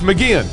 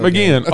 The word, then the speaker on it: McGeachin: It's McGeachin. – You said it right. – I said it right. – Way to go. – McGeachin on the show with us.